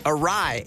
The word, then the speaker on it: awry: Another one, real short, it's awry, A-W-R-Y, awry.